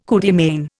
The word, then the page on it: coup de main: Indian